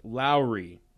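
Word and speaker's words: Lauri: It is pronounced Lowry.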